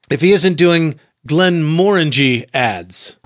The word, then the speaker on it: Glenmorangie: Morangie, okay. – Rhymes with orangey.